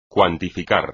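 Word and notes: cuantificar: español

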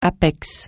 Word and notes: apex: francais

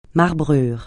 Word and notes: marbrure: francais

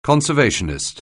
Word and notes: conservationist: anglais

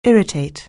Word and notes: irritate: anglais

